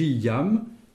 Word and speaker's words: jam: Gi yam estas legita. – Oui. – Yam, pour dire déjà, ça s'écrit J-A-M.